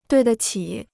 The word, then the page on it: 对得起: duì de qǐ